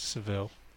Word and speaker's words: Seville: If you're doing the Spanish pronunciation, oh yes, if you're going for the English, it's Seville. – Sevilla.